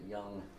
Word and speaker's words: Dung: He spells it D-U-N-G. But he actually pronounces it Young.